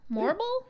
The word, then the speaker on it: Morrible: And you got, I actually don't know how to say her name, Madame Morrible? Morrible? – Yeah, you're right.